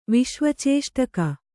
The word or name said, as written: ವಿಶ್ವಚೇಷ್ಟಕ